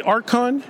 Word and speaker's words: Arkon: Did I say that right, Archon? – You said it beautifully.